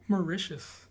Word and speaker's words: Mauritius: Like Zara Lennon, she's from What is it? – Mauritius. – Mauritius. Is that how you say it?